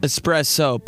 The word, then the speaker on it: espresso: D-J-A-N-G-O. The D is silent. – Espresso, but there's no X in it, so stop saying it like that.